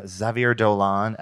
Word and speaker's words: Xavier Dolan: I'm going to make an effort tomorrow to see this Xavier Dolan episodic, The Night That Logan Died.